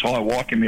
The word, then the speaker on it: High Wycombe: I think it's High Wycombe, you pronounce it in WA.